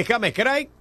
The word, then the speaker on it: James Craig: Banda municipal de James Craig.